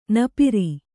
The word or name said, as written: ನಪ್ಪುರಿ